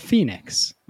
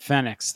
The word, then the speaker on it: Fenix: Furthermore, not my roommate, Chris, but the other Chris, I was pronouncing the name of this watch wrong, called the Fenix. And it's pronounced, of course, the Phoenix, like a freaking idiot would know that. Except for me, I was pronouncing it Phoenix the whole time.